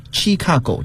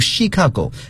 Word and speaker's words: Chicago: 然后呢，我还要更正大家，就是对芝加哥这个城市的发音，好多人呢都会误认为它应该读作 Chicago，这是错的，要读 Chicago，因为这个词来自于法语，可见早年它是法国人的殖民地。